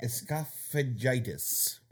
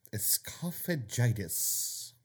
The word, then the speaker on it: esophagitis: Esophagitis. Esophagitis. – Okay. – Okay. – It sounds like you're trying to pronounce fajitas wrong, but also combine it with the word esophagus.